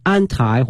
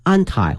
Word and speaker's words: anti: 首先我们看一下八年抗战 The Eight Year War 啊，八年的一个战争 Eight Year War of 抗日啊战争，叫 Anti 或 Anti-Japanese Resistance。第一个呢是抗啊，反对的意思 Anti 或 Anti A-N-T-I，两个发音哈，一呢可以念成 anti 可以，